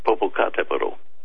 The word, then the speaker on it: Popocatépetl: Nothing specific, but they want me to ask you about Mount Popocatépetl volcano in Mexico. I don't even know if I pronounced that right. – It's Mount Popocatépetl.